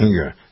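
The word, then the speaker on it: Engoron: Engeron, is that how you pronounce his name? – Uh-huh, that's right. – I think it's Engeron, yeah.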